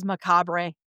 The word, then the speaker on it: macabre: For years, I thought it was macabre. – Yeah. – Oh, for sure. – Because macabre is M-A-C... – A-B. – A-B-R-E. Like, macabre. There's a B-R-E, and you don't say it.